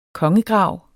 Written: kongegrav

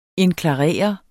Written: indklarere

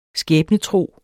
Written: skæbnetro

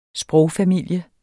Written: sprogfamilie

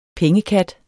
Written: pengekat

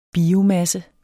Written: biomasse

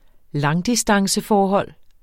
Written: langdistanceforhold

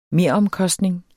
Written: mere

